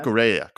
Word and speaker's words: Cucurella: Our two new players, Koulibaly and or Kurosella. I forget how to pronounce his name. – Yeah. Cucurella. – Cucurella. Yeah, sorry.